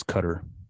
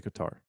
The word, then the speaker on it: Qatar: I believe it's Qatar. – I'm gonna pronounce it like the people on the airplane, that are from Qatar pronounce it, and they don't say Qatar, they say Qatar. – Oh, so yeah. I'm gonna go with how they say it. – Okay. – Because I did think the same thing as you, and then you actually get on the airline that's the namesake of the country, and they don't say it like that.